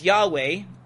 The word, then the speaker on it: Yahweh: So that's what you would pronounce as Yahweh.